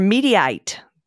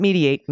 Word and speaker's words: Mediaite: First up, for Mediaite. Not mediate, Mediaite. I've been pronouncing it incorrectly. Thank you, by the way, to everyone who wrote in to school me on how to pronounce that.